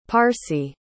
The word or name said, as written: parsi